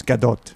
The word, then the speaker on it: Gadot: It's Gadot. – Gadot.